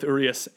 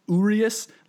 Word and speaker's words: Urias: I think it is Urias, like O-O, re, emphasis on that re and then is, but I think I've been calling him Urias a while.